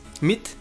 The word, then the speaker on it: Meade: ¿Meade? ¿Miad? ¿Cómo se dice? – Mit, Jorge Mit.